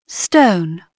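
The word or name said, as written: stone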